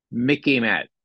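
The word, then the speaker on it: MICIMATT: You can remember it because it sort of rhymes with Mickey Mouse. Okay, here it is. Mickey Matt.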